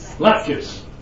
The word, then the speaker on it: latkes: Latkes.